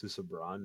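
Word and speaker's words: Susebron: And so now Susabron, which I realize I've been pronouncing it wrong. It's Susabron, not, I always thought it was born. I didn't realize that the O and the R.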